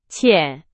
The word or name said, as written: 浅